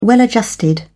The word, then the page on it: well-adjusted: British English